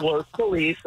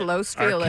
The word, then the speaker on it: Los Feliz: Mike and Los Feliz, good morning. – Los Feliz. – Good morning. – What is it? – Los Feliz. – Sorry. – No, it's Los Feliz.